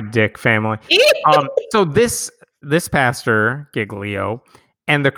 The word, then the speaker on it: Giglio: We're going to talk about, I'm pretty sure his name is pronounced Pastor Louis Giglio. Many people are like Giglio. No, I think Giglio is how he pronounces it.